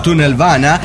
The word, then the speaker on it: Tunnelbana: Saludos, nave, desde un vagón de Tunnelbana, que es metro, no se lo estoy pronunciando como el culo, pero así se dice metro en sueco, Tunnelbana.